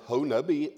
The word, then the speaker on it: Honobia: For years and years, this next week, the Kiamichi Men's Clinic will take place down at around Honubby, Oklahoma. Honubby, spelled Honobia, but pronounced Honubby.